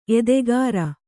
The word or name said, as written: ಎದೆಗಾರ